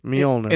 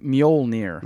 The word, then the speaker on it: Mjolnir: Therefore, we know how to say it. – Mjolnir. – And it is, in fact, Mjolnir.